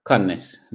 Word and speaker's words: Cannes: Cannes, the city on the at the sea, the coast. Cannes. – Oh, okay. – Cannes? – I am not geographically good.